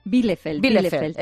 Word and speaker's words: Bielefeld: Bielefeld, eso.